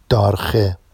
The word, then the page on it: dɑrxe: Vafsi